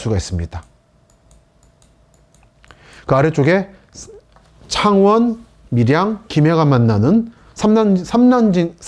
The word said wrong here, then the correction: 삼랑진: 삼랑진 쪽도 이렇게 피해가 큰 걸 알 수 있고요. 삼랑진 발음 어렵습니다. 매번 할 때마다 삼랑진이라고 또박또박 말씀드려야 되죠. 그 이 아래쪽은 김해 진영 쪽, 위는 한때 동남권 신공항이 들어올 예정지였던 밀양, 상남, 하남 지역이고요.